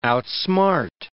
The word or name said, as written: outsmart